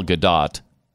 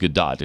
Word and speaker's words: Gadot: It also stars Ryan Reynolds and Gal Gadot. I guess it's pronounced Gadot instead of Gadot. Is that right? G-A-D-O-T.